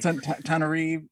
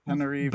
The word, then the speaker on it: Tananarive: Tananarive. – Yes. – Tananarive.